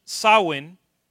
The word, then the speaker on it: Samhain: A través de los años, y estamos hablando de esto, a través de los años hay una fiesta que está escrito Sam Ain o Sam Ain, pero de hecho se traduce Samhain. No sé por qué, pero así se traduce. Eh, se traduce, no se pronuncia.